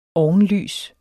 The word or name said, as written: ovenlys